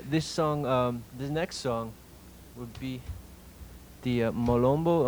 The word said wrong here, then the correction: Malambo: am I pronouncing? – Malambo. – Malambo, which actually, in this case, this is Yapanqui.